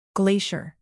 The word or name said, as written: glacier